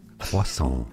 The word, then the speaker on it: croissant: In French, this dish or pastry is called croissant.